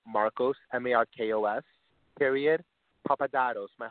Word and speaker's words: Papadatos: That's Marcos Papadatos, and I'll spell it as well. M-A-R-K-O-S P-A-P-A-D-A-T-O-S. Because I had to really work on the pronunciation. It's great. – A little tricky.